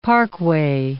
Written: parkway

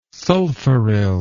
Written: sulfuryl

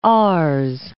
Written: RS

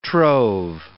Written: trove

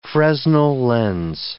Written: Fresnel lens

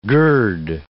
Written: gird

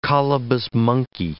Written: colobus monkey

